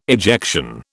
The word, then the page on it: ejection: British English